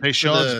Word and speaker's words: Peychaud's: Peychaud's bitters.